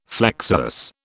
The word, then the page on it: flexuous: British English